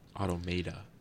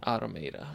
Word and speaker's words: Automata: I've heard people say Automata or Automata, whatever. – Automata, bro. – Automata, I'm sorry.